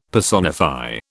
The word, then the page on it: personify: British English